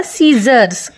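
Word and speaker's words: scissors: ಇನ್ನೊಂದು ಕತ್ರಿ, ಕಟ್ ಮಾಡ್ತೀವಲ್ಲ ಕತ್ರಿ, ಅದರ ಸ್ಪೆಲ್ ಮಾಡ್ತೀನಿ ನೋಡಿ, SCISSOR S, SCISSOR S, ಸೀಸರ್ಸ್. ಇಲ್ಲಿ ಸಿ ಇದೆ ಆದರೆ ಸೈಲೆಂಟ್ ಆಗಿದೆ, ಬರೀ ಎಸ್ನ ಉಚ್ಚಾರ ಬಂದ ಹಾಗೆ ಆಗ್ತಿದೆ ಅಲ್ವಾ, ಸೀಸರ್ಸ್.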